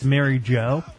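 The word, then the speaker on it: Marijo: It's spelled M-A-R-I-J-O. I think that's Mary Jo.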